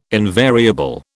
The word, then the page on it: invariable: British English